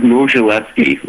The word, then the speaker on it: Modzelewski: They pronounce it actually like Modzelewski. We pronounce it Modzelewski, as if it's M-O-Z.